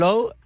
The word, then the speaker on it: Lowe: No, a ver, esta Ann Lowe, ¿Lowe lo pronuncio bien? – Sí.